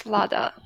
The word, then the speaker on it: Vlada: Yes, it's Vlada.